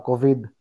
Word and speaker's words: COVID: Να σου πω, ε, το COVID, γιατί το, έτσι τονίζεται στο Ιωτά, COVID.